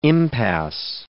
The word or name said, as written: impasse